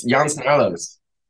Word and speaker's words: Jan Snarlos: Yeah, it's Jan Snarlos. It's like Juan Carlos, but just Jan Snarlos.